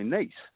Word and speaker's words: Nice: They are a very nice biscuit, and they are most definitely pronounced nice.